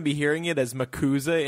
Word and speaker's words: MACUSA: My bit on it is kind of – it's very interesting that she would choose to have – the Mac USA, or actually she corrects everybody with the – she gives everybody a phonetic pronunciation here and says it's commonly pronounced as Macusa.